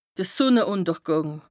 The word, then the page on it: de Sùnneùntergàng: Bas-Rhin; Strasbourg